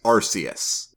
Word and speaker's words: Arceus: It's because I have always pronounced the god Pokemon, I've always pronounced their name as Arceus.